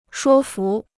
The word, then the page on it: 说服: shuō fú